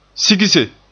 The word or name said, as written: sigse